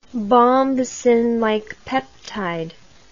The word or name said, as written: bombesin-like peptide